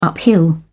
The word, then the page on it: uphill: British English